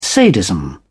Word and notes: sadism: British English

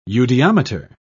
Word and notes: eudiometer: British English

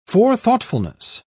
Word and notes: forethoughtfulness: British English

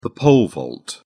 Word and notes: pole vault: British English

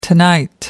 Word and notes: tonight: American English

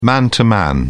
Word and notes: man to man: British English

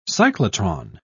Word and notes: cyclotron: British English

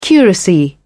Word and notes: curacy: British English

